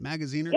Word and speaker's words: Magaziner: You reported that Magaziner, am I saying his name right? Magaziner? – Yep.